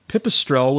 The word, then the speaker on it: Pipistrel: And then recently one of them, how do you say this? Pipistrel, that's right. – Pipistrel with their electric airplane won this thing.